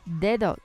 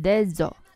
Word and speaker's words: dedo: 因为很多同学英语里面的 th the 也是发不好的，所以他们经常会把 the 发成 the。所以在西班牙语里也是一样，经常会把这个 the 发成 z，比如说 dedo 就变成了 dezo。